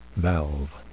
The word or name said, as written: valve